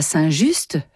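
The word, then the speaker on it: Saint-Just: Quant à Saint Just, prononcé Saint Jus par les Lyonnais, ce fut le XIIIe évêque de Lyon, au IVe siècle.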